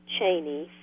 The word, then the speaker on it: Cheaney: Cheaney, C-H-E-A-N-E-Y.